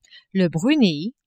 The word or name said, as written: Brunei